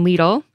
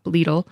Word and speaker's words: Lidl: And even grocery giants like Aldi and its German cousin Lidl, and that is how you pronounce it, I did look it up, Lidl,